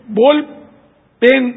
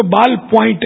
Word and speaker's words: بال پوائنٹ: جب بھی اس طرح کی چیزیں لکھیں تو یہ بول پین, اس کو بول پین بولتے ہے ویسے غالباً, اس کو بال پوائنٹ ہے صحیح تلفظ, بال پوائنٹ, لیکن بال پوائنٹ میں شاید لوگ ڈھونڈنے نکلے تو بیچنے والا بھی یہ نہیں ہے میرے پاس.